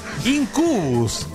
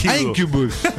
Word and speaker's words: Incubus: Incubus. – ¿Qué hay? – Incubus. Así se pronuncia.